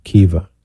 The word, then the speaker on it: Keevah: It's Kiva, K-E-E-V-A-H. That's how it's pronounced. – I'm sorry. – Kiva.